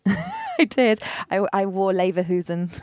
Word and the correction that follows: Lederhosen: You didn't wear Lederhosen.